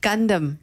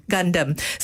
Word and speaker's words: Gundam: Is it Gundam? – Gundam. – Gundam. So, thanks for that.